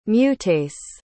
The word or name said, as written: mutase